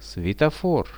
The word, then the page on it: svetofor: Russian